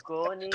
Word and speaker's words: Koenigsegg: Is it Koenigsegg? Or is Koenigsegg. – It Koenigsegg. – Koenigsegg. – Koenigsegg. Koenigsegg. Koenigsegg. Yep. Thank you.